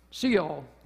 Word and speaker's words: Sheol: Lucas got up and he was reading a scripture and he got to a word and he's like, I don't know how to pronounce this. Sheol, that's how you pronounce it, Lucas, wherever you are.